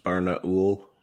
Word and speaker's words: Barnaul: How about some Barnall Performance Steel Case ammunition? – It's actually pronounced Barnaul.